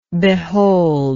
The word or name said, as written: beholder